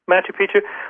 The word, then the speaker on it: Machu Picchu: How do you say that, Machu Picchu in Peru? – Machu Picchu.